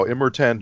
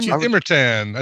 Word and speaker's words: Immortan: Is that what Immortan. I think you put the emphasis on the wrong syllable.